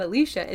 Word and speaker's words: Alicia: Also, I'd like to add that actually from studying Alicia Silverstone's TikTok, I learned that we've been pronouncing her name wrong. It's not Alicia, it's Alicia.